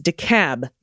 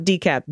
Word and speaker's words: DeKalb: You're so close to getting that pronunciation right. It's DeKalb, not DeKalb.